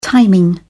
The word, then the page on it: timing: British English